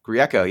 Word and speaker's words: Grieco: Grieco, yes.